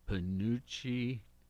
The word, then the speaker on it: penuche: National Penuche Fudge Day? – That's it. I said it right? Uh-huh. I believe you did.